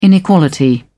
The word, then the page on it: inequality: British English